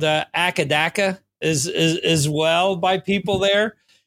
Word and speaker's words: AC/DC: In Australia, on the Conan O'Brien special that they had, they said that they pronounce it Akadaka.